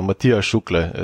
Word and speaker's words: Matija Šuklje: You could just drop the J. So Matija Šuklje is how you probably pronounce it.